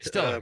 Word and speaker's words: Stiller: Ben Stoller was so nice. Did I get the name right? – Stiller, yeah, Ben Stiller, sure.